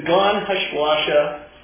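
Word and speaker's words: Gan HaShlosha: It's the Gan Hashuasha. That's the way you pronounce it. Sounds like sloshing water, doesn't it?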